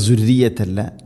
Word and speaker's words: ذريتي: ദുർറിയത്തി പറയരുത്, തർക്കീഖ് ചെയ്യണം, നേർപ്പിക്കണം. ദുർറിയത്തി, ദാലിൻ്റെ ഉച്ചാരണം ശ്രദ്ധിക്കുക, ദുർറിയത്തല്ല, ദുർറിയത്തി.